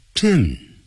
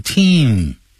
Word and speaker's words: Tim: One was called Tom and the other was called Tim. They were twin brothers, but they had different personalities. 我现在就已经开始诠释了，还没有开始讲解呢。好，我们现在就不诠释了，我们现在就来看一下 Tom and Tim were twins. 他说呢 Tom 跟 Tim 啊，就是这里有牵涉的发音 Tom 啊的音 Tim 发 it 短元音 Tim 变成 Team Tim were twins 也是短元音 it twins 啊，这样念变成 twins 啊，那就不对了。